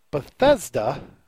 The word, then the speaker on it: Bethesda: Bethesda, the game company I have a hard time saying.